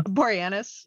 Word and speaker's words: Boreanaz: And honestly, I mean, look, David Bory, how do you say his name? Boreas something? – Boreanaz, yeah.